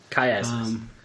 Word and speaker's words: chiasmus: Chiasmus. – It starts with a K in the phonetic pronunciation.